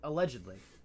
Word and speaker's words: Allgeier: Allegedly. – Alleged. Okay. Well, maybe his last name is pronounced allegedly.